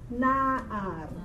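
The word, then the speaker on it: Na'ar (נער): I want you to pronounce it or read it as na'ar.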